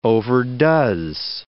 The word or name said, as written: overdoes